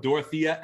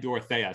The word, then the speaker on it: Dorothea: They pronounce it two different ways on stream when they were spoiling this card. They said both Dorothea and Dorothea.